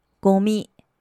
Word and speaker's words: ゴミ: 我们先来学“ 垃圾”这个单字，日文念成“ゴミ”，ゴミ，ゴミ，可燃垃圾叫做燃えるゴミ，燃えるゴミ，燃えるゴミ。燃える是个动词，中文的意思是燃烧、着火，后面加上ゴミ、垃圾这个字就成了可燃垃圾。